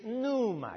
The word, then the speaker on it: pneuma: The Greek for spirit is pneuma. You see the P in front of it? Pneuma. Silent P, which means breath.